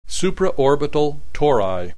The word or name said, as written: supraorbital tori